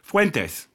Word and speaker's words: Fuentes: Fuentes. – Fuentes. – Like it's supposed to be pronounced when you're, you know, a Latino immigrant, yeah.